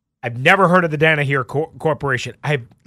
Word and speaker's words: Danaher: Rales is the co-founder of the Danaher, I believe is how you say it, Danaher Corporation, which is DC's largest company.